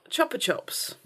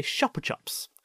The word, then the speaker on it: Chupa Chups: But that's not how you say it, apparently. – Oh, Chupa Chups. – You say Chupa Chups, because it's Spanish.